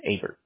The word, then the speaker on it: Aberts: Aberts. A-B-E-R-T-S. – A-B-D-R-T-S. Aberts. – A-B-E. – Oh, A-B-E. I'm sorry. – A-B-E-R-T-S. So it's pronounced Aberts.